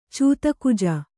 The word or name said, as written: ಚೂತಕುಜ